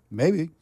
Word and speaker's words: Mabe: And I want to tell you that this next song is by Robert, I don't know how to say his last name, M-A-B-E, Mabe or Mabe? – Maybe. – Maybe.